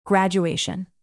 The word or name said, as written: graduation